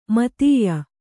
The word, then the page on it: ಮಿಥ್ಯ: mithya